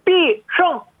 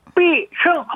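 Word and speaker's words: 비승 (必胜): 필승이라는 한자를 그대로 쓰고요. 발음도 비승! 비승!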